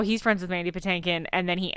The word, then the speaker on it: Patinkin: I'm friends with Mandy Patinkin, but he pronounces it Patankin.